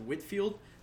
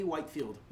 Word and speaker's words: Whitefield: If you also notice, my last name's pronounced Whitfield. But if someone asks me, like, on the phone or anything, and they need to write it down, I just say Whitefield. It's because it's spelled that way. I just mispronounce it on purpose.